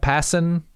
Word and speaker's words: Passan: It's Jeff Passan. That's how he pronounces his last name.